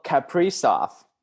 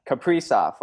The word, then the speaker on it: Kaprizov: Kirill Kaprizov. That is the correct pronunciation. – Really? – Yeah. Kaprizov, like Capri Sun, but Kaprizov.